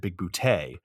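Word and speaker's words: Bigbooté: So he plays John Big Booty, as he pronounces it, Big Booty, head of operations at Yoyodyne Propulsions.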